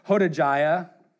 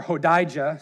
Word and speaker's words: Hodijah: Then the Levites, Jeshua, and Kadmiel, and Benaiah, and Hashabniah, and Sherebiah, and Hodijah, or Hodijah, excuse me. I'm sorry, I mispronounced that one.